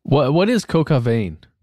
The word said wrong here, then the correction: coq au vin: Oh, I'm mispronouncing it. I'm not French. – Coq au vin. It's chicken with wine.